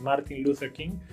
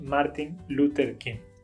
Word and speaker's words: Martin Luther King: Yo sé que su nombre no se pronuncia así en inglés, es Martin Luther King, pero bueno, en español lo pronunciamos así. En español decimos Martin Luther King.